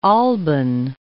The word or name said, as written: Alban Hills